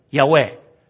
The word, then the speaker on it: Yahweh: In Hebrew, pronounced in this way, Yahweh, which means I am.